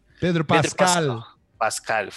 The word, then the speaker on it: Pedro Pascal: A gente falou pouco até de cada personagem, mas o personagem, primeiramente eu queria só dar aqui os parabéns pro Pedro Pascal, falei certo, né, o nome dele? – Pedro Pascal, falei certo, ok,